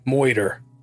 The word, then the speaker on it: murder: It was in this film that they noted the alternate pronunciation of the word murder as moiter, which was how Pauline would come to use the term in her diary entries a couple of days before the murder.